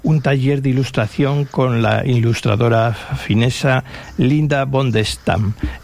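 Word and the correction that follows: Bondestam: Y Linda von der Stam, esa O se pronuncia como una U, Bundestam.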